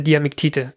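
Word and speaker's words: Diamiktit: dessen Namen ich nicht aussprechen kann, liegt. – Diese Diamektite, genau. – Also Diamektite. Genau.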